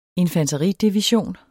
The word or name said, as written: infanteridivision